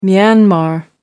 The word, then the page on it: myanmar: American English